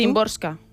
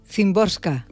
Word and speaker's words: Szymborska: A ver, ¿cómo lo pronuncias tú? – Szymborska. – Szymborska. – Entiendo yo que sí, por lo como yo he escuchado a la gente pronunciarlo.